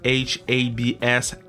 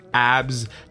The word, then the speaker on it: Habs: Uh, you can add the code ABS, H A B S, ABS, HABS, HABS, because in English it's not ABS abs, big abs. No, it's habs. You need to pronounce the H.